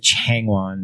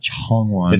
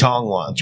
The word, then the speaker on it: Changwon: We pronounce it Changwon, not Chongwon. – It's Changwon.